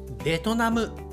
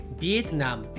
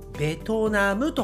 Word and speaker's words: ベトナム: ルさんのプロナンエーションがなんとなくねちょっとこうエグザジュレーションしている感じがします、えー、日本人の言い方はベトナムと言いますビエトナムではなくベトナム